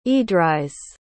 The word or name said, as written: edrys